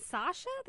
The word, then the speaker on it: Saoirse: Is it Sasha? That's how you say her name? – Yes, yes. – Oh, okay. – Even though it's spelled Irish.